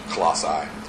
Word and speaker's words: Colossae: Many people pronounce it differently. I'm just going to go with Colossae.